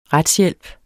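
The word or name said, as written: RETSHJÆLP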